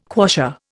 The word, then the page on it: quassia: Indian